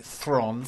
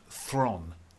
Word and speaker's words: Theron: This is the new film starring Charlize Theron and that is the correct pronunciation of her name. Did you know? Thron. – Mm-hmm. Theron. – No. It's pronounced Thron. – Thron. – She has said this. It's the South African.